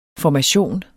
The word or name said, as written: formation